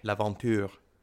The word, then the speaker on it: L'Aventure: Is that the right way to say it? The French way? How would you say it, like, as a French speaker? – L'Aventure. – Ooh, yeah, that was nicer.